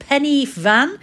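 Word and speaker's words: Pen y Fan: I'm absolutely knackered because today we have tackled Penyvan.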